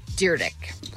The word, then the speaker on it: Dyrdek: John Cusack, Kathy Bates, Felicia Day, Elon Musk, and a guy who, I mean, I've always pronounced it Dyrdek, Rob Dyrdek. – Because that's his name. – Because that's how you say it.